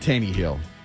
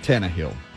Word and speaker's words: Tannehill: I said Tannehill instead of Tannehill, which is the correct way to pronounce his Tannehill.